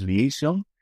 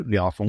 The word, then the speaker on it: Liaison: Depende, porque es una coproducción francesa-británica, con lo cual si quieres ir por el inglés es Liaison, y si quieres ir por francés, Liaison.